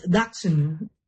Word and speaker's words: Dachshund: according to the German, it's Dachshund.